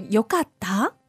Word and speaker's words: よかった: So yokatta. – Yokatta. – Yokatta. – So sort of rises a little bit like a question in English.